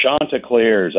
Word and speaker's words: Chanticleers: It's Chanticleers.